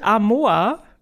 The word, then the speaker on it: Amoa: Ähm, wir fangen an mit Amoa so, glaube ich, spricht man das aus.